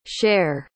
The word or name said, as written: sher